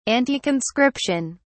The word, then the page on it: anticonscription: British English